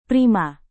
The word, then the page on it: prima: Indonesian